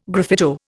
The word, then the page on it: graffito: Indian